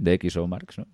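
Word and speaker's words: DxOMark: eh, según DXO Marx, eh, lo he dicho bien, DXO Marx, más o menos, ¿no? O... De Sí, creo que sí.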